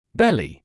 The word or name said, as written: belly